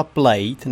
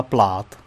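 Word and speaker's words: Plaid: No ono se to vyslovuje plát, ale... Já to čtu plate, teda. – Ale jako... Tak... – Je, je, jako správně to je plát, ale... Nebo je takový plát, ale jako plate to není,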